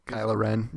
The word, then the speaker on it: Kylo Ren: He was dressed up as Kylo Ren, right? And I said that right? – Kylo Ren. Yeah.